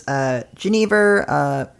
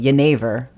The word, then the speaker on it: Genever: Well, the spirit of interest today is Genever. That's how I was taught to pronounce it. I feel like that's sort of an English butchering of it. It's a Scandinavian spirit. It's from the Netherlands and Belgium. The pronunciation is probably more appropriately Genever.